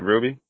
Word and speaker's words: RWBY: Ruby? – Right, it's, yeah, because... – Oh, that's how you pronounce it? – Yeah. You pronounce it Ruby.